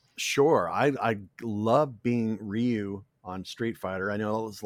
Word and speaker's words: Ryu: they said, okay, it's pronounced Ryu.